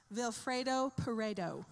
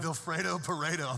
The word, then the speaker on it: Vilfredo Pareto: Vilfredo Pareto. – That's right. Does anybody else just want pasta when you hear his name? Come on. – Alfredo. – Vilfredo Pareto.